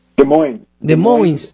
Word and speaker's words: Des Moines: Des Moines.